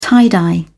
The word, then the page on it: tie-dye: British English